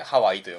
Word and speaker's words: 羽合: そう、羽が、合わさると書いて羽合いでハワイと読むんですけど、